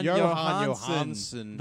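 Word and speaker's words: Jóhannsson: Well, according to envelope, it says Johnny Greenwood for You Were Never Really Here, and Joanne Johnson, Johansson, the late great.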